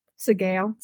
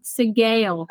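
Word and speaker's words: Sgaeyl: I'm going to pronounce their names very wrong, but Sayi. – Sigail. – Sigail.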